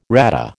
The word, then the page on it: RADA: American English